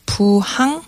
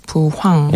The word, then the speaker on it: 부항: Korean, it's called puhang, mistakenly pronounced often as puhang.